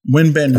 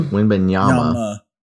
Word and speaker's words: Wembanyama: Wembanyama. Did I say it right? – Wembanyama. – I think so. – Wembanyama.